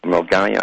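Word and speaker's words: Milngavie: But the Sunday Mail can reveal 5 other secondaries, Bishop Briggs Academy, Douglas Academy in Mulgavy, Mulgai, actually, they pronounce it, Kirkintilloch High and Bishop Briggs Schools, St. Ninian's High and Turnbull High will also get the scheme.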